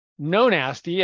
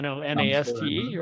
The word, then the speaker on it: Nonaste: And I thought, hey, what about this idea of Nonaste as a play on namaste, the way it's spelled, right? Nonaste, right?